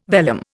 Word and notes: vellum: Indian

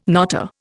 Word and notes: knotter: Indian

